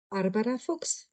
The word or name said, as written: Barbara Fuchs